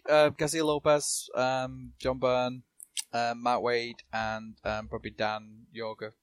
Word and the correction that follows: Jurgens: You said I can never say his name. – Jurgens.